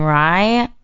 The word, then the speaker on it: Rai: It's Priya Anjali Rai. – How do you spell Priya? – Rai. – It's Priya, P-R-I-Y-A. Mm-hmm. Anjali is spelled A-N-J-A-L-I, last name Rai, R-A-I.